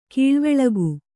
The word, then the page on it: ಕೊಳವಾಳಿಕೆ: Kannada